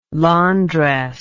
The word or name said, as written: londres